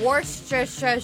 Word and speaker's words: Worcestershire: I put Worcestershire sauce on it. I believe that's the correct pronunciation of that word. – Worcestershire sauce? – Yeah.